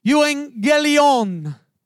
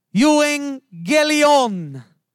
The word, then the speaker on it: euangelion: The word gospel in the Greek, it means, it's pronounced euangelion, euangelion.